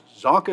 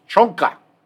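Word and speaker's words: Csonka: because Zonka is Hungarian, and she was like, it's pronounced Chonka. It is not Zonka.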